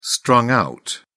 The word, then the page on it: strung-out: British English